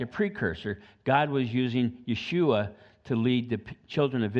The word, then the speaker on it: Yeshua: And it was Moses that changed his name to Joshua, which properly pronounced is Yeshua.